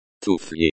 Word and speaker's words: Туфли: Туфли. Туфли, the F is pronounced like the F in foot. The I at the end is pronounced like the E in C.